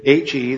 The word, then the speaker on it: He: In English it would be the H sound, but it's the letter He. And if you see in your Bibles, most Bibles will have that at the top. You see the word, the H-E, that's pronounced He.